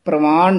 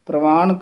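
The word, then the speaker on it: ਪਰਵਾਣ: पंच प्रमाण पंच प्रधान, ये भी बहुत ध्यान नाल उच्चारण करना, प्रमाण नहीं कहना। प्रवान कहांगे तो हेठा रारा लग जाएगा, प्रवान।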